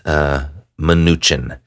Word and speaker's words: Mnuchin: So the Secretary of the Treasury, Steven Mnuchin, I think that's how you pronounce it, Mnuchin. There's an M-N. There's like no vowel in between there, so it's, I think it's Mnuchin.